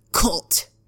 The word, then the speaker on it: KVLT: Right, or as black metalers would say, cult. If you're not cult, you're not black metal. It's spelled KVLT, by the way, all caps, to make it look more Scandinavian.